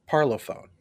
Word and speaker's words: Parlophone: Parlophone.